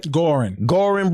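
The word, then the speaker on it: Goorin: Am I saying it right? – Gorin brothers. There you go.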